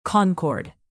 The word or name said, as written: concord